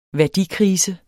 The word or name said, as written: værdikrise